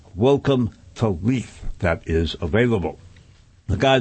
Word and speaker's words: Leith: Leith, L-E-I-T-H, pronounced like L-E-E-T-H, into a white supremacist compound.